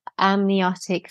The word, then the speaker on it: amniotic: But I entered the room and after some clicks, she said, "You're anemiotic," and you have to correct me if I'm saying it wrong here. Anemiotic So your amniotic fluid, amniotic, which is the water inside the bag that baby's lying in. Yep. – Amniotic fluid, thank you, is very low.